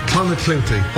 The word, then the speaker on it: Clonakilty: Pronouncing them was the issue. What did he call them? – From Cork. – Clonakilty. – Clonakilty. – Clonakilty, thank you.